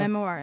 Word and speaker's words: memoir: og det, Jeg bare skjønner ikke helt hvorfor ikke synes at For hun snakker om engelsk eh, hva, hvordan uttaler det seg, memoir. – Altså, memoir, ja.